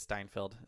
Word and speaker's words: Steinfeld: I get them confused because Steinfeld, or Stein, I think it's Steinfeld. Is that how you pronounce it? – Yeah. – It sounds like Seinfeld,